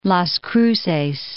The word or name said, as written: Las Cruces